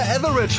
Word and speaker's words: Etheridge: Und Melissa Etheridge. Etheridge, ne? So heißt die Frau. Melissa Etheridge, Frank Falkenauer, so spricht man sie aus.